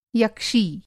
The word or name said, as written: यक्षी